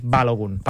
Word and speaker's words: Balogun: Balogun, sí, tot i que també hi ha qui diu que el cognom és d'origen nigerià i que llavors la pronunciació seria... – Hauria de ser Balogun.